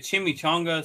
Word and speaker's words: Chimichanga: It was like a chimichanga, some sort of another. – Chimichanga, Derek. That's how we pronounce it.